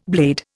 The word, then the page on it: blade: Indian